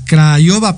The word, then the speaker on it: Craiova: Entonces, pues muy, muy padre y nuestra segunda mención es que nos comentan acá en producción que nos están escuchando desde Craiovia, mm, eh, Rumania, perdón, eh, Craiova, perdón, Rumania, ya no, sé ni pronunciarlo.